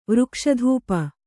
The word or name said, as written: ವೃಕ್ಷಧೂಪ